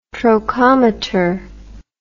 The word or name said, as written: trochometer